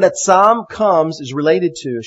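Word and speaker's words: atzam (עצם): In Hebrew, the word for bone or bones is atzam. If you know Hebrew, it's spelled ayin zadeh mem, atzam.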